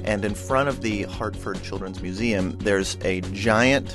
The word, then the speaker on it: Hartford: This is a most emailed story from the Hartford Current. Am I saying that correctly? I've never actually. – Yes. Hartford. Okay. It's Hartford. Yes.